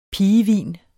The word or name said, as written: pigehvin